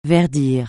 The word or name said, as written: verdir